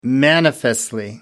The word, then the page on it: manifestly: American English